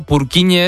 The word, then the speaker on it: Purkinje: En español sería Purkinje. – Purkinje. – Pero Purkinje es en checo, que es porque es el apellido del descubridor.